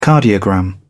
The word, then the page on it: cardiogram: British English